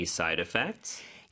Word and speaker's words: side effects: 여기는 R 발음이 아니라 side의 D 발음이 모음소리상에 껴서 real처럼 부드럽게 발음이 됩니다. 그래서 side effects가 되는 거죠.